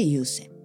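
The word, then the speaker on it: 湧泉: 漢字は湧く泉と書いて有線、足裏グーってした時に足裏見てね、へこむところです。ちょうど足裏こう 3 つに分けたら一番 3 分の 1ぐらいのところ、ちょっとねへこむところですね。そこ有線。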